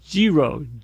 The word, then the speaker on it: Jiro: And there's one in particular, called Giro, J-I-R-O, Giro.